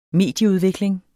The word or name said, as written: medieudvikling